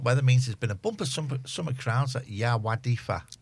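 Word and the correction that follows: Yr Wyddfa: Is that how you pronounce it? Erdwydfa. – Thank you.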